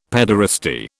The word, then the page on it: pederasty: British English